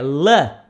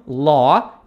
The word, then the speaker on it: love: E aí, prosseguindo, I love, lembrando que a pronúncia desta palavra aqui é la, não law, e sim la.